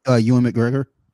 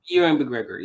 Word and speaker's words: Ewan McGregor: How do you say his name? – Ewan McGregor. – Ewan McGregor, yes.